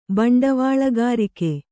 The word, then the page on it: ಬಂಡವಾಳಗಾರಿಕೆ: baṇḍavāḷagārike